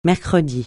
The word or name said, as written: mercredi